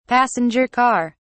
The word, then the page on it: passenger car: British English